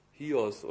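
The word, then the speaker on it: Hios (υἱός): Es bedeutet Hios oder so wird das ausgesprochen im Griechischen. Hios.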